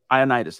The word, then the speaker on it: Ioannidis: How do you pronounce that cat's name, dude? Matt Ioannidis. – Ioannidis, that's right.